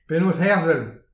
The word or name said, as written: penaos herzel